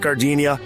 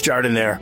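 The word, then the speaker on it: giardiniera: And guys, when you're at the counter, don't call it Gardenia. It's pronounced Jardinere.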